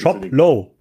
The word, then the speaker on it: Shoplo: Ähm, um dann wirklich Shoplo. – So Shoplo. – L-O, okay, nicht L-O-W. Ja, aber es spricht sich wie LO.